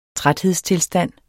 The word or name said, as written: træthedstilstand